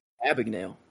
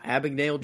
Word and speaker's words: Abagnale: Abagnale. Wow. Abagnale Jr. Yeah.